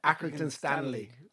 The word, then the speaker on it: Accrington Stanley: I can't pronounce Accrington. Accrington Santry. Yes. – Well, what's the real name of them? – Accrington Stanley.